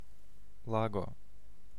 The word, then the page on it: lake: /ˈleɪk/; [leɪk]